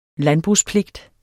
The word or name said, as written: landbrugspligt